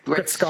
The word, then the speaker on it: Gretzky: And I'm so grateful they did because, anyway, I'm going to say it's Mr. Wayne Gretzky. – What? Yeah, you pronounced that just fine. Were you worried about the Wayne or the Gretzky? – The Gretzky. I wasn't sure if it's Gretzky, Gretzky. – Gretzky.